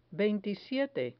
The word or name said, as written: veintisiete